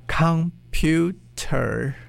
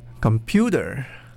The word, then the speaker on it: computer: It's not computer, it's computer, computer. Or the word banana is not banana.